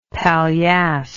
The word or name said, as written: paillasse